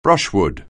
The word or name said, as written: brushwood